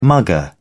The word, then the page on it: mugger: British English